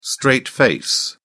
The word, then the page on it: straight face: British English